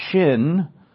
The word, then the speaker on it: Shin: Now, this generally, and that's your Hebrew word shin, which is pronounced with an SH sound.